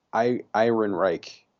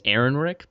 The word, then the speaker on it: Ehrenreich: It is, I'm going to hope I pronounce it right, Alden Ehrenreich. – I think it's Ehrenreich, but you're pretty close.